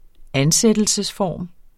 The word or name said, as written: ansættelsesform